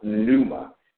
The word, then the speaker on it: pneuma: And that word is spelled P as in potato, N-E-U-M-A. And it's pronounced pneuma.